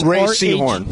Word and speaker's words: Rhea Seehorn: So if you're watching Better Call Saul, how do you pronounce the actress's first name who plays Kim Wexler? – It's Rhea Seehorn. – R-H-E-A. Rhea? – Yes, Rhea Seehorn.